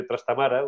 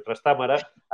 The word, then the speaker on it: Trastámara: estaba en el servicio Enrique de Trastamara, o de Trastámara, aquello histórico pero ayudo a mi señor.